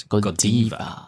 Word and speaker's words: Godiva: So it will be Godiva. Yes. – Godiva. – Yes. Not Godiva.